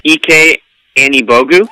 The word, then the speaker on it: Ike Anibogu: So what I'm going to do, though, is I'm going to select Ike Anibogu from UCLA. – Wait, start over. It's EK, we learned. – Seriously? – Oh, shoot. EK Anibogu? – Yep.